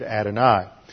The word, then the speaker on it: Adonai: When the Jews read it, they never pronounce the name of God. They always read it Adonai.